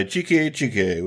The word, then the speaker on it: Xique Xique: So I'm going to throw down Too Much by Joe Turner and Preludio by Chike Chike, which is spelled X-I-Q-U-E. X-I-Q-U-E.